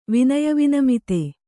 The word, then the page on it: ವಿನಯವಿನಮಿತ: kannada